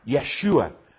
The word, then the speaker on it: Yeshua: But in the Aramaic that Jesus would have spoken, Yeshua was how it would have been pronounced.